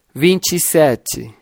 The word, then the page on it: 27: Portugués Br